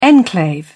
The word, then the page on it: enclave: British English